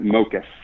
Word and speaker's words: Mockus: I'm never sure how to pronounce it, but Antonis Mockus, is that pretty close to correct? – Yeah, I think you pronounce it Mockus.